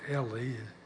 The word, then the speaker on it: Eli: Eli is the way it's pronounced.